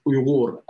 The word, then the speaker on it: Uyghur: So we pronounce it as Uyghur, and although majority of Uyghurs are Muslim, we have a significant minority Christian and Buddhist populations, among others.